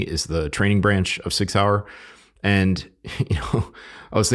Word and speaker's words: Sig Sauer: I want to recognize Sig Sauer, and that's the correct pronunciation, Sig Sauer. Sig Sauer is a leading firearms manufacturer,